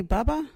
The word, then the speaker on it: baba: You got any Bubba? Or Bubba everywhere. Bubba is spelt B-A-B-A.